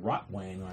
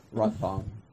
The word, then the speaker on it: Rotwang: Looks like Rotwang on here. – Rotvang. That's how you spell it.